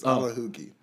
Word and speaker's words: Alekhogi: The K is silent, so it's Alekhogi.